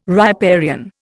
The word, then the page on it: riparian: Indian